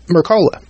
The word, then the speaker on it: Mercola: And Dr. Peter, I think, how you pronounce his last name? Is Mercola. – Yeah, Mercola.